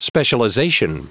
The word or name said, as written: specialization